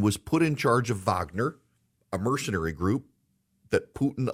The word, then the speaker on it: Wagner: Prigozhin, who is the head of the Wagner group, Wagner, it's spelled like Wagner, it's pronounced Wagner.